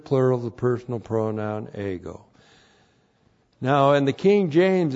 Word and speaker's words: ego: E-G, long O, ego.